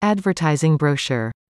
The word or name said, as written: advertising brochure